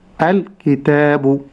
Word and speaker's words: الكتاب: أنتبه أن لا أزيد في ضمة الباء، فلا أقول بو الكتاب، ولكن أقول الكتاب ذلك الكتاب. – أنتبه عند النطق بالباء لا أقول بو ولكن أقول بو. – ما الفارق بين النطقين؟ – الجهر في الباء. – الجهر في الباء. – نعم لا أقول بو لكن أقول بو.